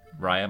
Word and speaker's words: Raya: Raya, am I saying that right? – Yes.